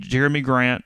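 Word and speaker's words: Jerami Grant: They can't afford to keep Jeremy Grant.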